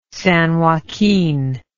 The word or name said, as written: San Joaquin